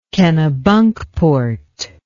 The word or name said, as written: Kennebunkport